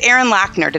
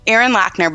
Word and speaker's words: Loechner: You know, it's Erin Loechner.